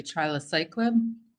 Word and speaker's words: trilaciclib: And I hope I pronounce it correctly, trilaciclib? – That's exactly right.